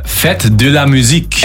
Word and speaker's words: Fête de la musique: Fête de la musique.